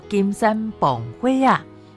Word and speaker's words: 金山蹦火仔: 我们在今天节目透过台湾文化词典为听众朋友来介绍这个全球仅存的传统语法，叫做金山蹦火仔，台语的发音叫做金山蹦灰啊。